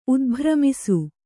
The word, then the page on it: ಉದ್ಭ್ರಮಿಸು: udbhramisu